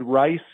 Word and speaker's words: Ryce: You can also find the show Legendary Life with Ted Rice, and my last name is spelled R-Y-C-E, and that's available anywhere you listen to podcasts.